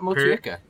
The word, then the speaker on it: Motueka: I don't know how to really pronounce this. Motueka? – Where is it? – Hops? – Sounds right. Motueka. Motueka. Yeah.